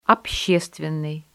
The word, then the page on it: общественный: Russian